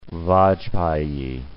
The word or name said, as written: Vajpayee